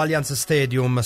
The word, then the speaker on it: Allianz Stadium: Si pronuncia Juventus o Allianz Stadium, stadium con la A proprio come la Juventus, mica inglese.